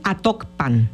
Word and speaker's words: Atocpan: No, solamente me acuerdo que me llamó la atención eso, que ellos pronunciaron Atocpan y yo conozco un Actopan en Hidalgo. – Uh-huh. Este es Atocpan. – Ajá, sí, San Pedro Atocpan.